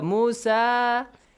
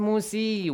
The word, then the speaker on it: Musa: So Musa. It's not pronounced Musi. We have a letter ya, but that's silent. It's written but not pronounced because this is the seat or chair for Mr. Hamza. So, whenever you see the dagger alif on the top of waw or ya, remember that this is.